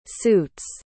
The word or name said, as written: suit's